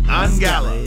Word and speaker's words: Ungala: Ungala. Make sure we're saying that right now. – Correct. Ungala. – Is that how Lady K was said? – Yes. – Ungala. Okay, we're doing it right then.